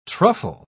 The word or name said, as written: truffle